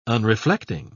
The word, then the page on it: unreflecting: British English